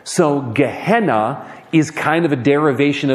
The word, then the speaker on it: Gehenna: Because there is another word that Jesus likes to use to refer to what we translate as hell, and it is pronounced Gehenna. Give me a good Gehenna.